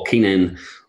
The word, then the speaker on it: Keenan: But yeah, Volume Fitness on Facebook, probably Volume Fitness on Instagram, Paul Keenan on Facebook, Keenan, and I always have to spell it because people never get it.